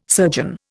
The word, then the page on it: surgeon: Indian